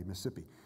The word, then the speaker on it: Mississippi: And if you've lived there as long as we did, there's only three syllables in that word, so you pronounce it correctly, Mississippi.